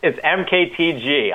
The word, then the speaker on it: MKTG: He is the VP of Digital at, now is it pronounced marketing? Because that's what I'm doing in my head. That's what I've been doing in my head. – It's MKTG. I don't blame you.